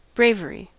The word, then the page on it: bravery: American English